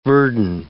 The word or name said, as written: verdin